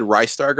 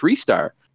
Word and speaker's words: Ristar: I always said Ristar growing up, like Rise Star.